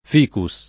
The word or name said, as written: ficus